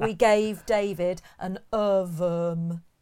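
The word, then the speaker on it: Ohrwurm: And the word is, I'm not sure how to pronounce it, so apologies in advance, David. Ovum. – How are you spelling that, please? – It's O-H-R-W-U-R-M. – Oh, yeah. – Ovum. And it's the German word for earworm.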